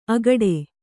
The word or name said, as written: ಆಗಡೆ